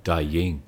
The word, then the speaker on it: Douyin: Douyin.